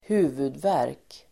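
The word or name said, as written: huvudvärk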